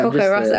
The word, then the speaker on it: Porco Rosso: You're putting a lot of Porco on the Sorry. Porco. – That's all right. – Porco Rosso. I don't know how to say it. – Well, that is how you say it.